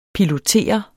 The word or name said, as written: pilotere